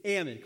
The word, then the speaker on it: almond: I know all of you call it almond, but say it with me, it's almond. Come on, say it with me, almond. All right, we're getting there. Just like in salmon, the L is silent.